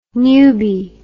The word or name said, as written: nube